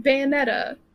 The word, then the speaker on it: Bayonetta: Next episode, we are going to be playing Bayonetta.